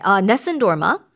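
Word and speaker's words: Nessun dorma: 영어식 발음이 뭐예요? – 네, 넷슨 도르마 똑같아요. – 비슷하네. – 네, 비슷해요.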